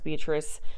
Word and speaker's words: Beatrice: And then the first claim under the Homestead Act was staked near Beatrice, I think it's pronounced Beatrice, Nebraska in 1863.